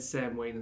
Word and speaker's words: Samhain: It's spelled weird, but said Samhain.